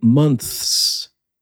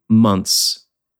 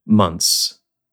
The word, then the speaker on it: months: Instead of saying months, simply say months, M-U-N-S. So for the singular, month, and for the plural, months. It's worth noting here that this shortcut is actually standard pronunciation.